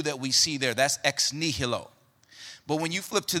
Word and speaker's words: ex nihilo: That new is called, or is pronounced, ex nihilo. Say ex nihilo.